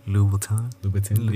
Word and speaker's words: Louboutin: It's the expensive like pumps brand, Lubo. Help me out here. Louboutin? – Louboutin? – Yeah.